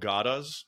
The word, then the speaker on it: gada: They were called gadas, I believe it's pronounced G-A-D-A.